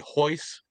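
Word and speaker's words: Royce: That would be Hoist Gracie. I know it's spelled with an R, but you know how those Gracies do. It's all the R first names are actually pronounced with an H.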